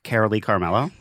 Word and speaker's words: Carolee Carmello: No, how do you say her name? – Carolee Carmello. – Thank you.